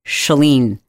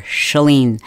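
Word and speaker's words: Chalene: My name is pronounced Shalene. Like, shut the door, Shalene.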